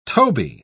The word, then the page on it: toby: British English